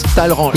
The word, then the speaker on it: Talleyrand: Moi, je dis Talleyrand. Qu'est-ce qu'on s'écrit Talleyrand, mais ça se prononce Talleyrand.